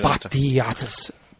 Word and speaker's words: Batiatis: Batiatis. Batiatis. That's how many syllables it is.